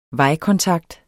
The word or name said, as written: vejkontakt